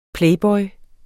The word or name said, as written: playboy